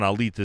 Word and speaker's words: Alit: Alit is spelled A-L-I-T.